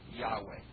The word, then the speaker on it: Yahweh: As you know, the Old Testament Jew was afraid to pronounce the covenant name for God, YHWH, we think is pronounced Yahweh.